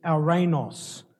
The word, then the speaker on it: ouranos: Now, the word heavenly in the Greek here is ouranos. Ouranos, it's spelled O-U-R-A-N-O-S, which means three things.